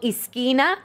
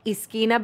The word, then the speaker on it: Izkina: It's called Esquina, and it's spelled I-Z-K-I-N-A, Esquina by Chef Papi.